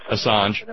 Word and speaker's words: Assange: I can never pronounce his name right. Julian Assange or Assange. Assange.